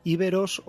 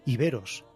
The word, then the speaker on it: iberos: Pues sí, efectivamente, se puede decir de las dos maneras. Íberos o iberos.